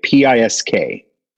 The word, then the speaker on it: PISK: It's a remix by P.I.S.K. – Nice. – Yeah, that's such a killer remix. I open my sets with that one. – I think it's pronounced Pisk.